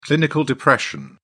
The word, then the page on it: clinical depression: British English